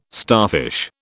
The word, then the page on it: starfish: British English